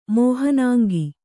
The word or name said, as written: ಮೋಹನಾಂಗ